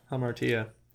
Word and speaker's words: hamartia: Oh, hey, Hamartia. – Yeah, Hamartia is how it's pronounced.